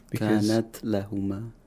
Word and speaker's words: كانت لهم: Kanat lahuma. – Kanat lahum. You pronounce the ta completely, mm-hmm. – but you quickly move the articulator to the place of articulation of lam.